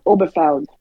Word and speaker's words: Obergefell: not sure how you pronounce this. Help me out. Obergefell. Is it Obergefell? Obergefell? – Obergefell.